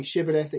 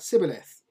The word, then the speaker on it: Shibboleth: But there seemed to be a difficulty among the children of Ephraim, some sort of speech impediment where they couldn't pronounce the sh sound and it came out s. So instead of saying Shibboleth, it came out Sibboleth.